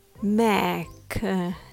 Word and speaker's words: mechanism: The word mechanism has four syllables and pronounced as mechanism. Let's go through it step by step. Number one, the first syllable, mech, is stressed. The e here has a short e sound, as in the word bed. So it's mech. Make sure to give this syllable emphasis.